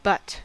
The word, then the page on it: but: American English